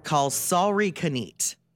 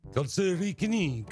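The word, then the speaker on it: kalsarikännit: There's a Finnish word. It's pronounced kalsarikanit. – Kalsarikanit. – Kalsarikanit. And this is for you, Fish.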